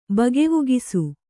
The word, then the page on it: ಬಗೆವುಗಿಸು: kannada